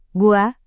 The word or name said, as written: gua